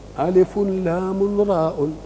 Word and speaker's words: الر: ألف لام راء، إنما قلنا إيه؟ ألف مسكن لام مسكن راء مسكن. يبقى الحروف الكلمة نفسها مبنية على إيه؟